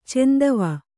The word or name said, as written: ಚಂದವ